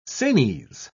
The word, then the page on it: sanies: British English